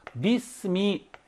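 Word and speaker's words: 비스미 (بسم): 그래서 어떻게 하느냐? 비스미, 비스미. 이렇게 얘기합니다. 비스미.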